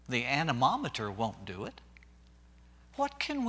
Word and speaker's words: anemometer: You know, we can measure the physical wind as it blows by using an instrument called the, I'm going to say it right, anemometer. If I can get all my syllables correctly.